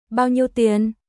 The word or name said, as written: Bao nhiêu tiền